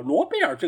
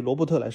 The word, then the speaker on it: Robert: 包括另外一个，其实也是阿森纳以前球员，一个中场球员皮雷，罗贝尔皮雷。哦，对了，罗贝尔这个是叫罗贝尔吧，而不是叫罗伯特，对吧？在法语里面。 – 对罗贝尔这个发音，针对于罗伯特来说，罗贝尔是可以打一百分。